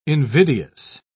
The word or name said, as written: invidious